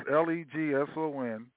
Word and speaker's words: Legson: "I Will Try" by Lexon. That's Legson. That's his first name, Lexon Kayira, and that's spelled K-A-Y-I-R-A. K-A-Y-I-R-A.